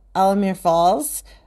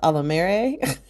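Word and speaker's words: Alamere Falls: So like Alamir Falls, which I didn't know that's how you're supposed to pronounce it. I always call it Alamere.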